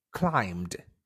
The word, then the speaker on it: climbed: So for words ending with ed, you find that if the word does not have t or d before ed at the end of that word, then it's pronounced d, or like a soft t. For example, climbed. He climbed the tree yesterday.